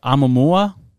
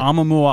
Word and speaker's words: Oumuamua: The Oumuamua object? – Perfect. – The Oumuamua object.